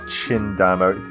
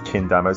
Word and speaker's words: Chindamo: It's not Chindamo. It's pronounced Kindamo. C-H-I-N-D-A-M-O.